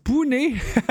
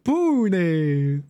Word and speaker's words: Pune: Looked it up, ça se prononce Pune. Pune.